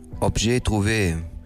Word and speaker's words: Objet Trouvé: Kannst du mir bitte sagen, wie es richtig ausgesprochen wird? – Objet Trouvé. Ich hatte Französisch, aber ja, ich gehe immer davon aus, dass das jetzt richtig ist.